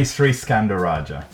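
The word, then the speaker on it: Sriskandarajah: And who better understands the force of good by selling secondhand clothes than the CEO of Oxfam, Danny Sriskandaladra. And I know I've said that wrong, Danny, so will you correct me? – No worries, Danny Sriskandaraja.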